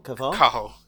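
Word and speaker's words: Cathal: Cahal. It's a silent T.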